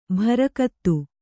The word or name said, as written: ಬಾರುಗುತ್ತು